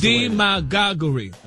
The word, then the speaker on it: demagoguery: You got D-E, M-A-G-A-H-G-U-H, re. – Well, that's demagoguery. – That's the way the dictionary broke it down. – The pronunciation, like the emphasis is on the D.